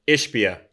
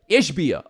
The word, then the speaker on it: Ishbia: Somebody in my mentions said about, was it Ishbia? Is that how you say his name? – Ishbia. – Ishbia, okay.